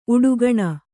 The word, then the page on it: ಉಡುಗಣ: Kannada